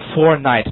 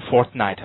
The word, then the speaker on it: fortnight: 在这儿呢一定要记住，这儿有个失去爆破的现象发生了，这个 t 啊和这个 n 两个都是英文中的辅音，两个辅音放在一起肯定要发生失去爆破的现象，前面一个 t 呢失去它的音啊，叫做 fortnight, 不要读成 fortnight, OK, fortnight 叫做两星期 fortnight 啊。